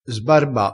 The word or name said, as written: sbarba